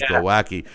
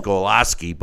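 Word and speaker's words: Głowacki: I always thought it was Glowacki, but it's Golaski. But in any event, there's no S.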